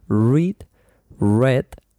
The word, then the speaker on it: read: Es read, you know, R-E-A-D. But you pronounce read, red. Yes, you pronounce like the color red. Read, red.